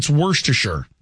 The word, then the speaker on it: Worcestershire: It's Worcestershire. That's how it's pronounced.